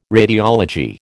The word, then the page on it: radiology: American English